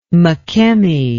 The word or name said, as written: Makemie